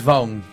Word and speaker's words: Vaughn: Vaughn. – Matthew Vaughn. No sé cómo se pronuncia bien. – Como esa. Vince Vaughn.